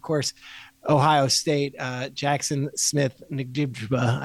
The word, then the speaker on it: Njigba: You pronounce the N and then just say Jigba. – Njigba. Njigba.